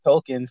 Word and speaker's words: Tolkien: There'd be no Game of Thrones without Tolkien. So, I would like to. – I think you said that wrong. There'd be no. Oh yeah, no, you said it right.